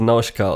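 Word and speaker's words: Nausicaä: Ja, so ist der deutsche Titel und die Frage ist Nausicaa, weil im Japanischen wird es ja die ganze Zeit so Nauschka ausgesprochen.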